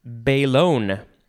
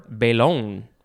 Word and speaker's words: belon: And then our last word is belon, or belon. Couple slightly different pronunciations. It is spelled B-E-L-O-N, noun from 1940.